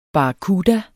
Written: barracuda

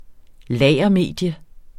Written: lagermedium

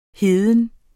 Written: heden

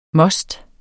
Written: MOSTERNES

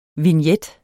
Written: VIGNETTENS

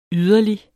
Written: yderlig

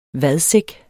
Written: vadsæk

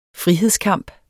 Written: frihedskamp

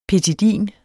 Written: petidin

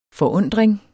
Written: forundring

